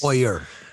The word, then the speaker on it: Poyer: Micah Hyde and Poye not not enough gets said about those two beauties. Poyer, Poyer, Poyer. There we go. See, not enough gets said. I didn't pronounce his name right.